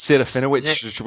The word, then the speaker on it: Serafinowicz: Serafinowicz, which, which is, yeah.